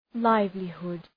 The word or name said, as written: livelihood